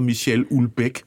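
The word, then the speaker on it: Michel Houellebecq: men han hedder Michel Ulbæk, har jeg lært at udtale det som.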